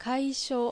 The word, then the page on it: Kaisho: Japanese